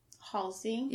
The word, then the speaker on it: Halsey: Halsey.